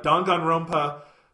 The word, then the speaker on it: Danganronpa: Danganronpa 3 is coming out.